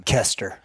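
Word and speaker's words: Koester: Kester. – Silent Kevin O. Kester.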